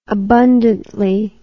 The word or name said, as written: abundantly